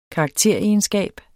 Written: karakteregenskab

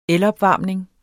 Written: elopvarmning

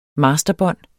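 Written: masterbånd

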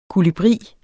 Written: kolibri

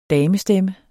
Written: damestemme

